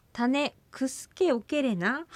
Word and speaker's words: タネクスケオケレナ: タネクスケっていうふうにちょっとね発音されましたけど、クスケ、クスケオケレナというのが正しいアイヌ語の発音だと思いますね。 – はい、タネクスケオケレナ。